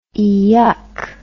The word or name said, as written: eeyuck